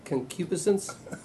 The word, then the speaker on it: concupiscence: Concupiscence? – Wow.